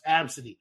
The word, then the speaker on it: Abcde: Oh, and I would have their fucking name is literally pronounced Absidy.